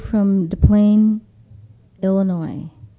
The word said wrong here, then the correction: Des Plaines: Did I say it right? DePlains? Des Plaines.